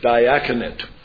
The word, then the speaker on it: diaconate: And I did check my most recent dictionary, and that is the most accepted pronunciation of the word diaconate.